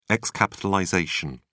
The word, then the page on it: xc: British English